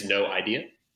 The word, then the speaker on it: NOEYEDA: My name is NOEYEDA. That's how we pronounce it.